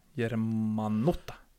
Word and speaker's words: Germanotta: Och sen då, det fanns även en, en som har bytt namn också, som hette Joanne Angelina Jermanotta. Germanotta.